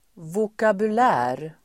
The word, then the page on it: vokabulär: [vokabul'ä:r]